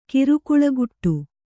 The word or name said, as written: ಕಿರುಕುಳಗುಟ್ಟು